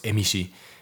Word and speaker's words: Emishi: Sin embargo, haciendo un paréntesis aquí en contorno a los Emishi, que también se puede pronunciar Enzo, y eso ha llevado a la confusión o a la creencia de, de trazarlos en similitud con los Ainu, pero son personas distintas.